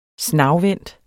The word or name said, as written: snagvendt